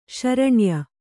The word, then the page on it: ಶರಣ್ಯ: śaraṇya; Kannada